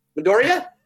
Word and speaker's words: Midoriya: So we've got Midoriya winning the first one. – Hey, because you got his name right. – Midoriya? – You're learning.